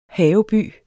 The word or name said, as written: haveby